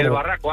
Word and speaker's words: El Barraco: Desde el Barraco, Ávila.